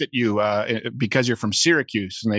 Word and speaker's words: Syracuse: Syracuse is pronounced Syracuse. Like, I'm from Syracuse. I know how to say Syria. Why am I putting it in an Italian accent?